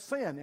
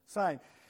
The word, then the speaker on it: sine: And then she said, that's not how you say it, and that's not what that means. How do you say it? Sine? Sine.